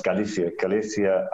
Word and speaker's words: Galicia: in places in Europe like Galicia, and I'm saying that wrong, it's Galicia and France, Britain and so on, would be kind of like what happened here in Russia.